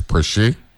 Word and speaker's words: Preche: Alex Preche, even though it's spelled like pressure.